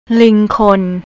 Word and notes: lingkhon: thai

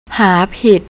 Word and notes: harpid: thai

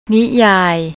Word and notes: niyai: thai

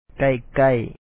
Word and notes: ລ້ານຊ້າງ: làan sàaŋ

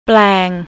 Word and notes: plang: thai

